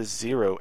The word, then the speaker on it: Xero: And then I guess you pronounce the Zero, X-E-R-O, was another Genesis crossover.